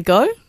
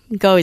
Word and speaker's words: Ngo: The name of the book is Jim, an Australian Hero, and look up my name, Maria Goh. Goh is spelled N-G-O, and you should be able to find it.